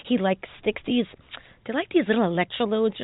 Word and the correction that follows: electrodes: Electrodes? – Thank you.